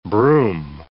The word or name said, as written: brume